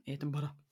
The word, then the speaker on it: Edinburgh: Edinburgh. – Edinburgh.